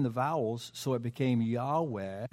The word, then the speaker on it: Yahweh: He is Yahweh. That's our pronunciation. Written out, it's YHWH. Or YWW. Let me get that right. YHWH. That's right. I got it right. Yahweh.